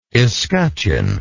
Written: escutcheoned

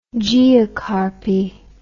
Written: geocarpic

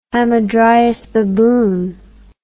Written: hamadryas baboon

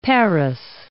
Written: Paris

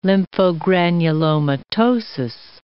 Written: lymphogranulomatosis

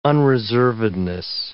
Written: unreservedness

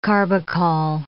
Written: carbachol

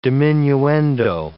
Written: diminuendo

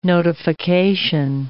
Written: notification

